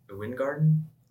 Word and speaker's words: Weingarten: I could be wrong, and I think it might actually be a German name just because of the way that it's spelled. Because it's, I think it's WeinGarten. – Mm-hmm. – And so when I first heard it, I thought people were saying wine garden, right? – Yeah.